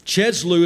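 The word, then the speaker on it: Kislev: Now, earlier, it said in the month of Cheslu, is how I pronounce it.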